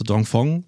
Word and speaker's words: Dongfeng: Wir haben ja zum Beispiel jetzt auch auf der Auto-China-Messe gesehen, dass zum Beispiel auch nicht klassische Automobile, so Dongfeng,